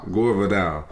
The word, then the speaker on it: Gore Vidal: What's some things that you, that people, like Gore Vidal, how do you say it? – Gore Vidal.